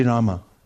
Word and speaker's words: Rama: Is it Hari Ram or Hari Rama? – Hari Rama. If you pronounce the last syllable also, Hari Rama, as you're pronouncing the name, then you also won't fall into bad habits.